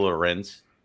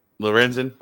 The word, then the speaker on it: Lorenzen: Let's talk about Mr. Michael Lorenz. I said that right? – Lorenzen.